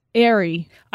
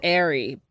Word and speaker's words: Aire: The name is spelled A-I-R-E. Airy. – I thought it was Airy, but it's Air.